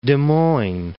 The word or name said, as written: Des Moines